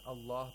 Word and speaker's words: Allah: The lam is pronounced with tawlil. Why? – Because actually it is preceded by a letter with actually a dhamma here. – Exactly. Okay, if we're pronouncing Allah by itself, Allahu la ilaha illa huwa al-hayyul qayyum, because we're pronouncing it with a fatha, mm-hmm.